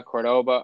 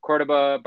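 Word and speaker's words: Córdoba: We went to Sevilla, Cordoba, I said it wrong. Cordoba, Barcelona, Madrid, Toledo, like all Valencia, all over the place.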